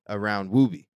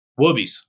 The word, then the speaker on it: Woobies: What's interesting too, is you guys really built a community around Woobie, right? Am I saying it right? Is it Woobie or Woobies? – Woobies with an S. Woobies.